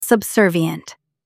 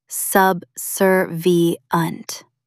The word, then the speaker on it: subservient: Sub, sir, v, unt. Meaning, overly submissive, obedient like a servant, and giving up your own independence. Roots, sub, under, and servire, to serve, literally mean bowing down under someone else's command. Stress the second syllable. Subserviunt. Say it out loud. Subservient.